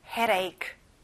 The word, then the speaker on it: headache: 두통, headache. 자, 가운데 D 발음은 잘 안 나요. 부드럽게 내보세요. headache.